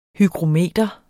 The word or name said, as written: hygrometer